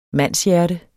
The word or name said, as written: mandshjerte